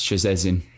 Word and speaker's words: Szczecin: Yeah, that's Szczecin. – S-Z-C-Z-E-C-I-N.